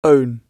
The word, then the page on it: own: British English